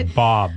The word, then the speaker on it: Bobb: Stephanie Bobb, B O B B.